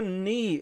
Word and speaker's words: knee: When you see K-N, don't pronounce the K. Just say knee, okay? When you say I don't know, it is K-N, but you say N, okay? Knee in this case.